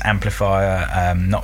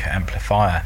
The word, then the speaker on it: Amplifire: It's a really nice looking demo, and that's Amplifier, it's spelled AMPLIFIRE, Amplifier. This, like I say, is an example of a SID doing a much more current sounding piece of house music, essentially.